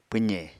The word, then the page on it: pwze: Apatani